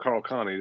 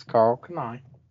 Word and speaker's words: Karl Kani: I said Carl Connie, didn't I? – That's Karl Kani.